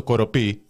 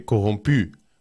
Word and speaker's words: corrompue: Ένα ενθουσιασμό στα μέσα κοινωνικής δικτύωσης και μια αναζήτηση του τι σχέση έχει η Εύα Καϊλή με το κοροπή. Ο κύριος δικηγόρος εδώ, ο κύριος συνήγορος, θέλει να πει κογομπή.